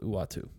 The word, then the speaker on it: Uatu: Uatu. – Uatu. Uatu. U-A-T-U. That's how it's spelled, Uatu. I guess it's spelled, it's pronounced Uatu.